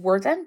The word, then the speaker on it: Worthen: So I would go, my name is Representative Worthen. That's spelled W-O-R-T-H-E-N.